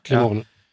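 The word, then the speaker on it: Climeon: som då är något som jag tror uttalas Climon, som har väl någon... – Climon.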